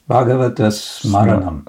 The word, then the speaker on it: Bhagavata Smaranam: Now we have book with headline Bhagavata Smaranam. Is it right pronounced? Yes, Bhagavata Smaranam.